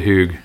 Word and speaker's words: Hygge: I hope I'm really saying this right. Every time I say it, I'm like, Hygge. – Hygge. – We call it Hygge.